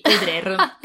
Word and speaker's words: Audrer: Io so solo che AU si legge O, quindi Audrer, credo, poi insomma ci perdonerete.